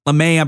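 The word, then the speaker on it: Lamet: And then relief pitching, they got Chris Stammen, Emilio Pagan, Tim Hill, Danielson Lamette. LeMay, I believe is how you say his name, excuse me.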